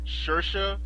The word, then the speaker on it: Saoirse: how it's Saoirse, but it's written like Sauroys or whatever.